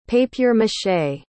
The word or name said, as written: papier-mâché